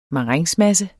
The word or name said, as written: marengsmasse